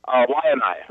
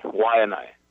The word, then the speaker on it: Waianae: Did I pronounce it close to right? – Yeah, close to right. – Okay, and the place? – Waianae, Waianae. – Waianae. All right.